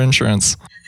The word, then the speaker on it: insurance: Wait, how do you say insurance? – I say insurance. – Insurance. – Like the in is very insurance. Short.